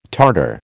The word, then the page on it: Tartar: American English